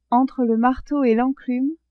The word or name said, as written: entre le marteau et l'enclume